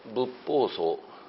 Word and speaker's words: 仏法僧: 発音するときにはみんな仏法僧というふうにこの言うわけですね。